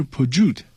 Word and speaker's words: Peugeot: 对，但是白如只是音译过来的嘛，它应该发音应该是 Peugeot。